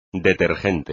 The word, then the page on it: detergente: espagnol